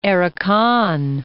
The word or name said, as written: Arakan